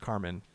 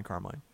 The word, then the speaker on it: Carmine: But I have heard it's pronounced Carmen. I've heard it pronounced both, Carmen and Carmine. All right. But so I think you can say it either way.